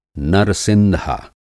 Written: नर-सिंधा

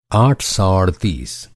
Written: अड़तीस

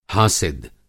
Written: हासिद

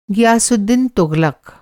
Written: गयासुद्दीन तुग़लक़

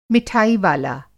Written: मिठाईवाला

